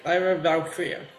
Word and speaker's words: Lyra Valkyria: Did I pronounce that right? Did I butchered it? – No, that's right. Lyra Valkyria.